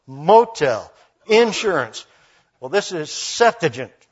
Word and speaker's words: Septuagint: By the way, for those of you who find that Septuagint just rolls off of our gringo tongues nicely, you got to get the emphasis on the right syllable It is the first one, and it is Septuagint.